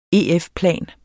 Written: EF-plan

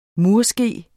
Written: murske

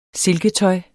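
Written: silketøj

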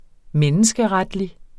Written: RETSNORM

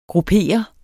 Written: gruppere

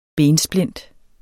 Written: bensplint

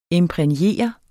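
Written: IMPRÆGNERE

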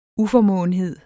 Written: uformåenhed

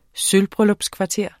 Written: sølvbryllupskvarter

